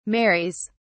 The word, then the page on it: marries: British English